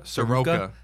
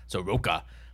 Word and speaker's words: Soroka: We debated about how to say his name, but what did we settle on? Soroka? – Soroka. – Soroka. Mike Soroka.